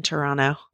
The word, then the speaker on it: Toronto: don't pronounce the second T in Toronto.